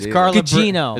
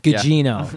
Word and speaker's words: Gugino: Why don't you try saying her last name correctly? – I don't know how to say it. – Gugino. – Is Carla Bruschetta in the movie? – She's Italian. Gugino.